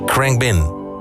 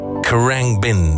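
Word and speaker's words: Khruangbin: Ja, niet zo handig, want heel veel mensen weten niet hoe ze het uitspreken. Krangbin. Krangbin. Dat is Thais. Het is een Thais woord voor vliegende motor of vliegtuig eigenlijk, Krangbin.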